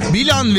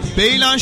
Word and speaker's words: Belen: Belen kasabası. Bilan veya Beylan şeklinde de telaffuzu olunan Belen kelimesi, Türkçe'de iki dağ arasındaki yer, geçit anlamına gelir.